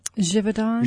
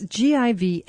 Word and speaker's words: Givaudan: how do you pronounce it? Giron? – Givaudan. – Givaudan. – It's Givaudan.